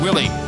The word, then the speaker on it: Wiley: Love hearing Robert Willie, pronounced Willie, even though you spell it like Wiley.